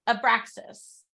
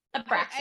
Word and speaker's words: Abraxas: Abraxas. – Okay. I wasn't far off. – Abraxas. – I said something along that in my head. – Yeah. – I think I might have. – And Abraxas is like Abraxas, his, because he's a bait beast, his spikes have been sawed off.